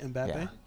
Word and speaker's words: Mbappe: Mbappe? Is that how you say it? Mbappe? – Yeah.